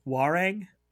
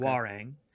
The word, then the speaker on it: Hwoarang: Hwoarang. His name is okay. Hwoarang.